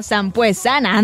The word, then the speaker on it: Sampuesana: Vámonos ahorita con los pica pica, esta rolita que se llama Cumbia Sampuesana. No creo que sí se dice, ¿no? Sampuesana. Ándale, sí lo dije bien, hombre.